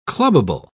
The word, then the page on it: clubable: British English